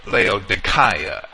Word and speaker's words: Laodicea: Paul also mentioned Hierapolis, which was near to Laodicea, or Laodicea, as I would rather pronounce it from the Greek.